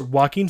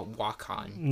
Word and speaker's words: Joaquin: It's Joaquin Phoenix. We just said it the same way. Joaquin Phoenix. – Earlier you said Shaw Joaquin. – No, I didn't. I said Joaquin.